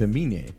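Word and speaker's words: Aminé: So his name is Amine. I was saying it wrong, too. It's Amine.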